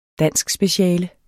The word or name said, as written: danskspeciale